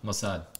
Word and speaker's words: Mossad: Just a reminder of the Mossad. Is how you pronounce it? The motto? – Mossad? – Mossad.